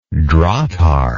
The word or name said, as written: drahthaar